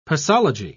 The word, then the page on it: posology: British English